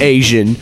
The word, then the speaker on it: AZN: His name's Asian. – Is he? – Okay. And it's spelled A-Z-N.